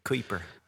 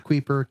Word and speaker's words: Kuiper: I just tell them it rhymes with hyper or diaper. – Kuiper. – Yeah, Kuiper, Kipper. It's the U and the I. It's a Dutch thing.